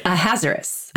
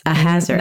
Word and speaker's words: Ahasuerus: And because I want to correct myself from whatever pronunciation I made from the beginning, just in case you guys are silly enough to repeat what we say, it's Ahasuerus. – Ahasuerus. – Not whatever I said before.